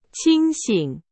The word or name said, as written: 清醒